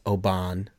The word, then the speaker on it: Oban: No matter how many times I just tell them, just ignore the A. Pretend the A's not there. Oban. – Yeah, Oban.